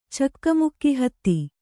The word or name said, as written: ಚಕ್ಕಮುಕ್ಕಿಹತ್ತಿ